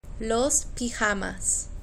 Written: los pijamas

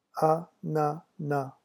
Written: ananas

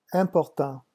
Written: important

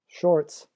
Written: shorts

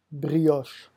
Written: brioche

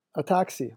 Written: a taxi

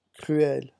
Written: cruel

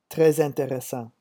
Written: tres interessant

